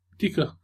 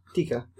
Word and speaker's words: Taika: T-A-I-K-A. How do you pronounce that? – T-A-I-K-A. – Taika. – Tika. – Tika.